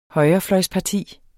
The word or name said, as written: højrefløjsparti